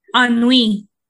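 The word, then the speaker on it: ennui: Her existential Inui, am I saying that right? I-N-N-U-I? – Anui. – Anui. Damn, I was way off.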